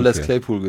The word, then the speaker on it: Les Claypool: Wird das so ausgesprochen oder ist es französisch? Les Claypool. – Nö, der hat, also derjenige, der mir die Sprachnachrichten geschickt hat, hat schon Les Claypool gesagt.